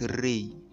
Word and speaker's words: Ra: The word Ra is the name of an ancient god called Ra, pronounced Re.